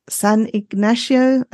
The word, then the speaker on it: San Ignacio: And the most fabulous experience of all was spending time in San Ignacio, I think it's called, Lagoon. I've probably got that totally wrong. – It's a good pronunciation, though, as a Spanish speaker.